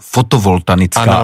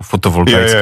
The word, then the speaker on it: fotovoltaická: Třeba říkali fotovoltanická elektrárna. – Na to jsem byl taky zvyklý, jasný. Jo. – A já vždycky tak nenápadně, vždycky, když to řekli, tak jsem jenom tak, víš, jako fotovoltaická.